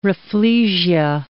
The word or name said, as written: rafflesia